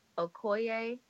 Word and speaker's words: Okoye: So anyway, I found her captivating, and I also really loved Okoye.